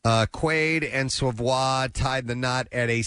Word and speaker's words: Savoie: I guess that's how you say her name, S-A-V-O-I-E, Savoie.